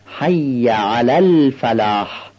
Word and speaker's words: حی علی الفلاح: دقت کنید اینجا لام نزد ف قمری هستش و ادغام نمیشه، نمیشه بگیم حی علی الفلاح. با عنایت این لام رو تلفظ کنید، حی علی الفلاح.